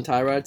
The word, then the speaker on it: Tyrod: You know how everybody calls him Tyrod Taylor? – Mm-hmm. – Apparently he came out in an interview and said his name is actually pronounced Tyrod Taylor.